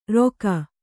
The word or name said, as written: ರೊಖ